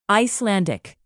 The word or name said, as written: icelandic